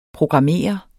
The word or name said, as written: programmere